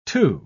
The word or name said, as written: two